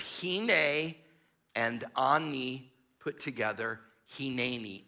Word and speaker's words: Hineni: Hineni.